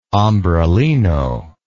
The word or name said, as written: ombrellino